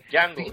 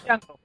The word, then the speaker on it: Django: Django. – Sí, es Django. – Django. La D no se pronuncia.